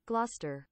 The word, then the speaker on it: Gloucester: Gloucester. – Gloucester. That does not look like that's pronounced like that.